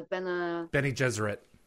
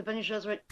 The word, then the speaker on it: Bene Gesserit: The Bene Gesserit. – Right, the Bene Gesserit.